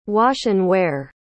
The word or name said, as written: wash-and-wear